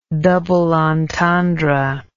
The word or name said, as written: double entendre